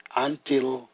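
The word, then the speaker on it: antilepsis: Gifts of helps, the Greek word is antilipsis, pronounced antilapsis, meaning relief.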